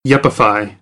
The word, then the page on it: yuppify: American English